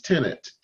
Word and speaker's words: Tennent: You can just put it, Google me at TJ and then put a space, and my last name is Tennant T E N N E N T.